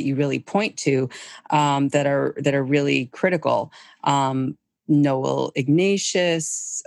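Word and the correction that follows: Ignatiev: you talk about him. Why is he important? – Well, Noel Ignatieff. – Ignatieff.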